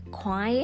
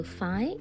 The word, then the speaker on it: khoái: thực ra nó là từ khoái á khoái nghĩa là thích á nhưng mà người miền tây á thì phát âm thành từ phái nên mọi người nhớ ghi nhận từ này để hiểu nha kiểu ví dụ